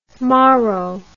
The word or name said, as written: morro